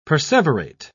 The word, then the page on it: perseverate: British English